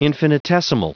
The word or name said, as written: infinitesimal